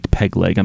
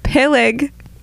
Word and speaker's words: Peleg: I'm sorry, that's Ortit Pegleg. I'm sorry, you pronounced that wrong.